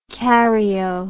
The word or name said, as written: caryo